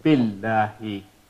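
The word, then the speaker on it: Billahi (بِاللَّهِ): So it became Billahi. So now we are not pronouncing this Hamza. Isn't that right? This Hamza is not pronounced.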